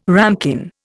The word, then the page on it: ramekin: Indian